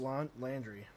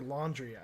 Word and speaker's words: Landry: Jarvis Landry. I keep wanting to say Laundry.